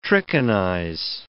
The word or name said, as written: trichinize